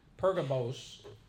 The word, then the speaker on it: Pergamos: And this is church at Pergamum or Pergamos. Pergamos. Sometimes it's pronounced that way.